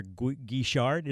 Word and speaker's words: Guichard: Tommy Tucker, WWL, joined by Dr. Claire Guichard.